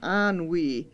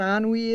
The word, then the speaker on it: ennui: who died of ennui. Do you know what ennui is? – Mm-mm. – That's French for boredom. It's spelled E-N-N-U-I.